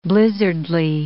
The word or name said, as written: blizzardly